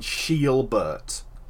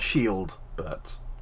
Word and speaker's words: Shielbert: It's spelt without a W. Swordwood and Shielbert. Not Shieldbert.